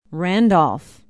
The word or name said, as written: randolph